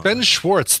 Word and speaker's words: Schwartz: Ben Schwartz, thank you, McCarrick. Yes, I added a little bit of extra. I added an extra syllable to Schwartz.